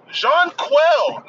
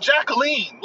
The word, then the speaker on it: Jonquel: C-O-P-P-E-R. Not C-O-O-P-E-R. Kalea Copper. Jonquel, not Jacqueline.